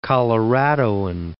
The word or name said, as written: Coloradoan